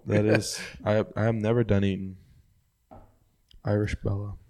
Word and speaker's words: Dunedin: Dunedin. That's how it's pronounced.